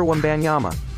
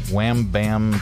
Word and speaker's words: Wembanyama: How do you pronounce this French professional basketball player's name? – Victor Wembanyama.